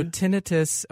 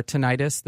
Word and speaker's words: tinnitus: Yeah, so tinnitus or tinnitus, that people pronounce it both ways, is ringing in the ears.